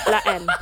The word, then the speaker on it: La Haine: You just pretend the H is there. So it's la Haine.